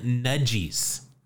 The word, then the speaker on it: Nadjy: So Cy was toiling for the Canton Nudgies. Now that is spelled N-A-D-J-Y. The Nudgies.